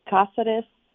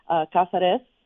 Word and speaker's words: Cáceres: My name is Gabriela Cáceres Kelly. – Cáceres. So it's pronounced Casa, like a house on the res where I grew up. Cáceres Kelly.